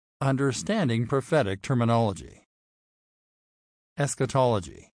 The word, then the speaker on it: eschatology: It is pronounced eschatology. The doctrine of eschatology can be divided into two distinct categories.